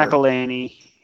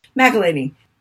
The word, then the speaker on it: McElhinney: Curtis McElhinney. I don't know if I said his name right. Whatever. – McElhinney. Yeah. – McElhinney.